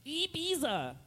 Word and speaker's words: Ibiza: You do know that you're well traveled because you pronounce it correctly. – That way. You don't think. Ibiza. – Ibiza. – He's in Ibiza. – That's how you say it's Spanish.